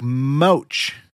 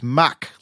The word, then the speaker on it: Mack: He had a comment that said, quote, "In past episodes, you've mentioned Gene Mack, but you pronounce it like mouch. Mouch. It's Mack, like the airspeed," end quote.